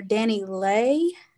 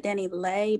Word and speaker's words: DaniLeigh: Well, this week, so the singer Danileigh, she pronounces it Danileigh, but, you know, I guess I'm country. I say Lee, Danileigh.